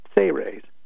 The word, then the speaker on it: Seres: And it has a series of 53 pages, an extensive technique section in the back of that book, and it's spelled S-E-R-E-S and pronounced Say-Raise, as if it was spelled S-A-Y hyphen R-A-Y-S.